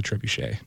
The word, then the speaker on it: trebuchet: That is the French pronunciation. But if you look it up in Oxford's English Dictionary, the anglicized version is trebuchet.